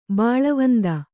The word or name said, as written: ಬಲವಂತ